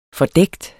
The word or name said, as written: fordækt